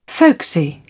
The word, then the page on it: folksy: British English